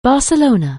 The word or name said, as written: Barcelona